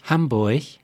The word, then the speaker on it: Hamburg: In Hamburg, richtig ausgesprochen eigentlich Hamburg.